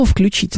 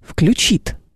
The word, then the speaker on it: включит: Не включит. – Включит. – Включит, да. Или я скажу: Илюш, ты включишь нам свет? Скажет: конечно, включу. Вы понимаете, да? Или мы его с вами сами включим. В общем, я долго могу еще продолжать эту всю историю. Факт остается фактом, ударение мы всегда ставим здесь на последний слог.